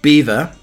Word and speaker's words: Belvoir: He came up with a head- headline Saturday Night uh, Beaver, rhyming the Leic- Leicestershire seat correctly with fever, where it's pronounced uh, spelt Belvoir.